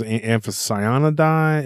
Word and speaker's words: Amphicyonidae: Or is Amphicyonidae? Is that how you say it, Ken? – Yeah, beautiful. That was perfect Latin.